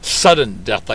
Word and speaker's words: Sudden Death: And people always think of the way I pronounce things that I'm saying southern death, but it's like sudden death, like sudden death overtime that you would get like in a soccer match or a hockey game.